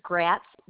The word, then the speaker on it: Gratz: It's Gratz, G-R-A-T as in Tom, Z as in zebra, hyphen Pearson.